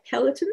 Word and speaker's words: Peloton: Peloton? – Yes.